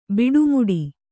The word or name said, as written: ಬಿಡುಮುಡಿ